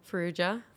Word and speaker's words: Farrugia: Ferrugia.